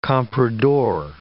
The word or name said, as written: compradore